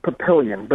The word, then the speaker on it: Papillion: We go now to Papillon, or maybe it's pronounced otherwise, Nebraska, listening on the wonderful Spirit Catholic Radio Network. Did I pronounce your town right? – It's Papillion, but that's okay.